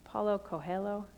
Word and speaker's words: Paulo Coelho: Only then, says the author Paulo Coelho, how do you say that name? Paulo Coelho? Thank you, my Spanish speakers.